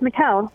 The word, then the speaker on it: Michel: Mikael in Seattle, am I saying your name right? – Yeah, it's Mikael. – Oh, Mikael, I'm sorry.